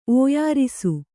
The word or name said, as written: ಒಯ್ಯಾರಿಸು